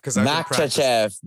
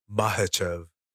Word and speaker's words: Makhachev: I've Makhachev. Makhachev. – It has Makhachev. You have to keep that other one silent. It's Makhachev. – Makhachev.